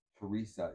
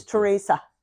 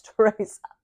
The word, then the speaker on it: Teresa: Not to really divert from your movie, but my first thing about Mother Teresa is that you pronounced her name wrong. – It's Teresa. It's Teresa.